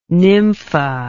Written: nympha

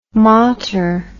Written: motter

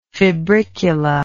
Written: febricula

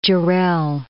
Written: Jarrell

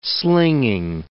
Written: slinging

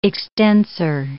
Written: extensor

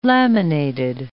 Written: laminated

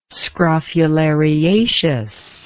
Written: scrophulariaceous

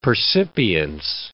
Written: percipience

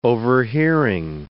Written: overhearing

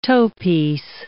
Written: toepiece

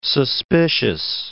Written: suspicious

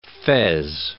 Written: fez

